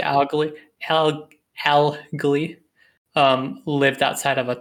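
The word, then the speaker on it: Algli: Augli. It's A-L-G-L-I. I thought it was Agli until I saw the first L there.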